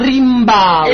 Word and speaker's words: Rimbaud: Allora si pronuncia Arthur Rimbaud.